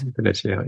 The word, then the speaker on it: Veneziano: some amplitude called the Veneziano, I think. Veneziano? I don't know how to pronounce it. – Yeah, I just read it. – Veneziano, yeah.